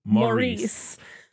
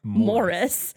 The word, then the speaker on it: Maurice: requested this that we cover this, saying that actually British people pronounce it Morris.